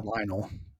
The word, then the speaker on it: Lionel: Lionel.